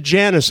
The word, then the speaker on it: Janus: you pronounce it Janus. I'm glad to hear that for reasons I won't go into. And that's what I would have called it, too.